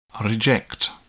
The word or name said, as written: reject